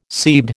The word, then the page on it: seabed: American English